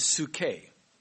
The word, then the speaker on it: psuche: And that Greek word is psuche. I'll spell it here. The Greek word is P-S-U-C-H-E, psuche.